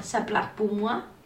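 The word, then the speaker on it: Ça plane pour moi: Lo que pasa es que es conocido por este single del 77 que se llama Saplas Pumua. – Qué bien lo has pronunciado, ¿eh? Sí. Se nota ahí el francés pilota.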